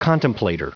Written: contemplator

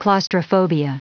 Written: claustrophobia

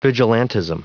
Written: vigilantism

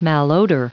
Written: malodor

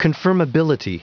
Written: confirmability

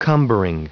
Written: cumbering

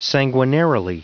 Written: sanguinarily

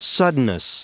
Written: suddenness

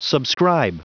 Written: subscribe

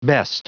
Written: best

